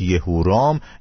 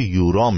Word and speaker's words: یهورام: بهش یهورام یا یورام میگفتن یورام و یهورام یه اسم با دو تلفظ متفاوته